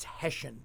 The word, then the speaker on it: Hession: good because of how it's spelled: H E S S I O N, Hessian, Hessen. It's Hessian. Hessian. – So Hessian, like okay. the Hessian soldiers, although not the Hessian soldiers because that's I A N, we're I O N, but it is Hessian.